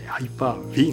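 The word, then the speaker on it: Hyper-V: もちろんこれ海外の方はね V なんていうことなくてですね、ハイパー V が正しい発音となります。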